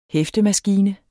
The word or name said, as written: hæftemaskine